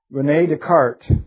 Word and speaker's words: Descartes: But Rene Descartes, you don't pronounce any of those S's.